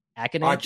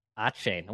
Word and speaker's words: Achane: Number five, Devin, is it Akinade? – Achane.